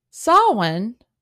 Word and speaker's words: Samhain: So the word Samhain, Samhain, is how Americans pronounce it. Now, in the British pronunciation, it's son, like son, S-O, it's almost like S-O-W-N.